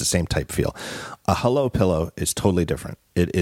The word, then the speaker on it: Hullo: It's our good friends at Hullo Pillow. It's H-U-L-L-O, but they pronounce it Hullo.